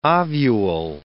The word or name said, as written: ovule